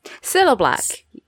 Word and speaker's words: Cilla Black: Our first question is from friend of the show, patron of the show, Siberian Pine, who asks, has seen the Cilla Black? Am I saying that right? – Cilla Black.